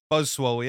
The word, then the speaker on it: Buzzwole: Buzzwole? Is that how you pronounce it? Buzzwole? – Buzzwole, yeah.